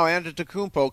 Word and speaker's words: Antetokounmpo: Everybody knows how to say the name now, Antetokounmpo.